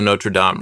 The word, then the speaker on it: Notre Dame: So what I'm actually talking about is like the Notre Dame, right? It burnt. – Can you pronounce that properly, please? – Notre Dame. – Thank you.